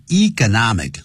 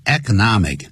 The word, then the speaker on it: economic: 这家公司估计他们会失去损失大约$3,000,000 this year, 就是今年呢、啊。由于什么关系呢 ?because of the economic, 或是 economic, 两个发音都可以 ,economic, economic, 都可以就经济方面的就是这个挫败